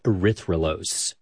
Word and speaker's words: erythrulose: And they scanned it for radio signals of different molecules, which is how they found a four-carbon sugar called erythrulose.